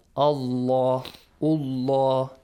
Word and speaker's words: Allah: So we had a rule that whenever we have a fatha sound before the word Allah, the word or a dhamma sound, the word Allah will be pronounced heavily. – Heavy. – Yeah. Allah, Allah. But whenever we have a kasra, like, sound before, it's lightly like pronounced.